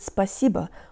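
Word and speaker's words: spasibo (спасибо): Hey guys, and today you'll learn how to pronounce the word spasibo, or thanks. The O at the end sounds like a schwa, and the tongue is high and forward for the middle syllable and lower for the rest.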